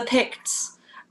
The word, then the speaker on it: Picts: How did you come across, how did you say it, the Picton? – The Picts.